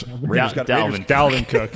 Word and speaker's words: Dalvin Cook: Devin Cook against the Raiders. – Dalvin Cook.